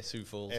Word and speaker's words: Sioux Falls: And you just said, I was trying to figure out how to pronounce it because I was reading it, Sioux Falls. – Yeah, Sioux Falls. – S-I-O-U-X.